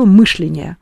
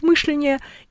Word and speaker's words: мышление: И как э, Помните, Горбачев говорил мышление. Да. За ним э, дикторы Первого канала стали говорить мышление, и какое-то время люди, значит, говорили мышление, мышление, в общем, не знали, как говорить.